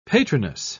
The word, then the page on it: patroness: British English